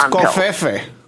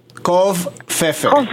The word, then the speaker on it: covfefe: He's thought it's pronounced confifi. Covfefe.